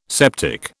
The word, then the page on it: septic: British English